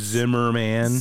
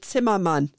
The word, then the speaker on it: Zimmermann: But we didn't understand it as being our name because they did it with the English pronunciation. And We had no idea that like that's Zimmerman. – Zimmerman. – Yeah. – And it, in German, you would say Zimmermann.